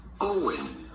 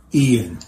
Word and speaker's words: Eoin: We are looking at how to pronounce this traditional Irish name. How do you go about pronouncing it? Not like it's spelled, but simply Eoin. – Eoin.